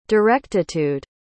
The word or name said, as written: directitude